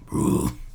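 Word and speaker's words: Brühl: I think that's how you pronounce it. It has the U with like the two dots. Who's like ooh Uber. It's Bruhl. Bruhl.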